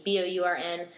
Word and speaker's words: Bourn: My last name, Bourn, like Jason Bourn, but no E. Bourn.